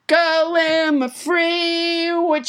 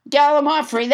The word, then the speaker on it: gallimaufry: Let's never forget Gallimaufry, which I pronounced wrong until nobody, James Hyder, corrected me. Gallimaufry, that's how you say it.